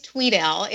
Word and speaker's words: Tweddale: It actually is Tweedale. It's spelled phonetically incorrect. So it is spelled with one E, but it's pronounced as if there are two E's.